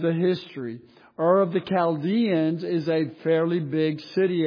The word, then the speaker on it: Chaldeans: And you see that in verse 31, Ur of the Chaldeans, or Chaldeans if you want to pronounce it that way.